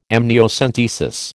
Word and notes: amniocentesis: American English

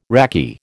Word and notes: raki: American English

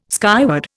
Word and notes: skyward: Indian

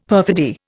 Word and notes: perfidy: Indian

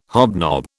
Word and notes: hobnob: British English

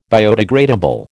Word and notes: biodegradable: American English

